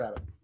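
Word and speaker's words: Rabban: I was saying Raben because there's 2 B's. Raben.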